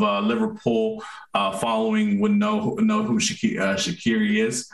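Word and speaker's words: Shaqiri: You pronounced it right. Shakiri. You pronounced it right.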